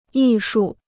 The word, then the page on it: 艺术: yì shù